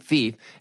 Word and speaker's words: Phoef: Phoef Sutton, and of course, he pronounces his name Phoef and spells it P-H-O-E-F.